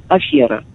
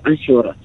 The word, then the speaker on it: афера: Ну, понятно, что очень хочется, когда ударение на «е», произносить это все, афера, да, ну это совершенно неверно, то есть крупнейшая ошибка. Запомним, что в, это, в этом слове нет все.